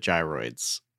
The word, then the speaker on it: gyroids: I'm like really excited for the gyroids. I'm really excited for those to come back. Am I saying that right? – Gyroids.